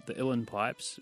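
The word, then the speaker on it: uilleann: Kathleen, you play a pretty unique instrument, I want to say, the Illan pipes.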